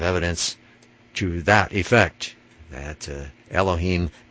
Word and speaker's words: Elohim: Then Hebrew 430 is Elohim, or gods, Strong's number 430, pronounced Elohim, the plural of 433, which means rulers, judges, sublime ones, or there it says divine ones, messengers, and they say angels.